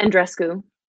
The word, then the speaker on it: Andreescu: Bianca Andreescu.